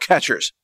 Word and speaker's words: QuEChERS: And it's actually spelled a very weird way. I think it's spelled Q-U-E-C-H-E-R-S. It's very non-intuitive, but it means something like quick and cheap extraction process, or I forgot what the acronym actually means. It's called catchers with a Q.